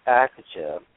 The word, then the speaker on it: Akatha: Well, the word Zahaji is called a foreign word by other people, just like the word Akata, A-K-A-T-H-A. And the T-H in higher Sanskrit language is pronounced as a hard T. So the word Akata is how it's pronounced.